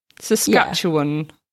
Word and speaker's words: Saskatchewan: They eventually fell in love with a pretty house in La Ronge, Saskatchewan. And I hope that I've pronounced that properly. Saskatchewan.